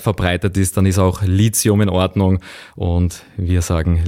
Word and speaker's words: Lithium: Eigentlich heißt es Lithium und nicht Lithium.